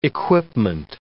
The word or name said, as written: equipment